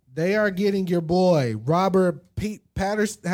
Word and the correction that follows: Pattinson: Robert Pattinson to play a young Batman.